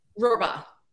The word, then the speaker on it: Rohrbaugh: Rohrbaugh.